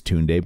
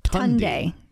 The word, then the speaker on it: Tunde: And one of the Williams sisters is named, the name is spelt the same as Toon Day, but they pronounced it Tundi.